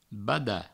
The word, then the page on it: badat: Provençal